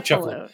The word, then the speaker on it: Cefalù: I was wondering how to pronounce that. Cefalù. Yeah, Cefalù. Okay.